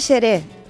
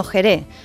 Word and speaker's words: Jerez: Os cuento, la letra X no siempre se, se pronuncia como KS, ¿no? Como en extraño o exclusivo. Pues hay palabras en las que no representa ese sonido, que es el suyo actual, sino el de la J, como en México, que se escribe con X, y Texas, que se dice el estado de Texas, no de Tesa. ¿eh? Tampoco se dice seré, sino Jerez, aunque el mundo del periodismo deportivo veo que se dice de vez en cuando, ¿vale?